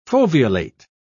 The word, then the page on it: foveolate: British English